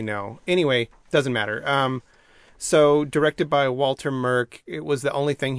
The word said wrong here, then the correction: Murch: Merck or Murch? – Murch, sorry. – Spell it. – M-U-R-C-H. – Oh, Okay, like Lurch, but with an M.